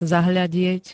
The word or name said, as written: zahladiet